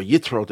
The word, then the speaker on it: Yitro: The word Yisro, or Yitro, depending on your pronunciation,